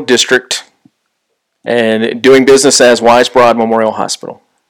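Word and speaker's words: Weisbrod: Wisebrod, did I say it correctly? – I think you said it correctly, Rich.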